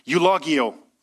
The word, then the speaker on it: eulogeo: Eulogio. Eulogio. It's spelled E-U-L-O-G-E-O. It's a bit like eulogy.